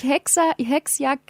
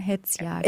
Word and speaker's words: Hetzjagd: Und nicht Hexjagd gesagt, sondern Hetzjagd.